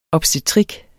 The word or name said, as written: obstetrik